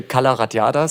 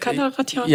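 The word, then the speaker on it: Cala Ratjada: Es müsste Calaratjada sein. – So einfach spricht man das, Calaratjada? – Ja, ja, okay.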